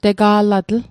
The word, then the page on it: tega'latl: Mi'gmaq